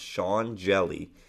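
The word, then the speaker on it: Hjelle: His name is Sean Jelly, spelled H-J-E-L-L-E. I checked the pronunciation. Pronounced Sean Jelly.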